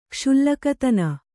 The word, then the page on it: ಕ್ಷುಲ್ಲಕತನ: kannada